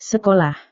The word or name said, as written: sekolah